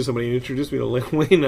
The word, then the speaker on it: Len Wein: The, meeting Len Wein was big too. Is that how pronounced? – Len Wein.